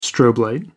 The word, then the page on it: strobe: American English